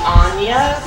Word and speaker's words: Aine: My name's Angie, my mersona is Lady Anya, that is spelled A-I-N-E, it's Celtic.